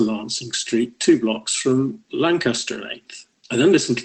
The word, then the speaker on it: Lancaster: And the first thing I picked up was that although all the official transcripts say Tippit's last call was at Lancaster at 8th, note that Lancaster in English as well as US English is three syllables, Tippit actually said Lansing at 8th.